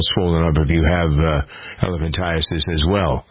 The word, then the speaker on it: elephantiasis: These problems, the limbs, the interesting thing about this lymphatic problem is it's referred to often as elephantitis. Elephantiasis, I got to pronounce that correctly.